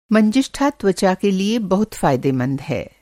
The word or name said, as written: मंजिष्ठा